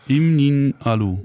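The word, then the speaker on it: Im Nin'alu: Im Nialu. Nee. – Spricht man das so aus? Ja. Im Nialu. – Ja, ganz genau.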